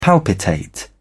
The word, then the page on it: palpitate: British English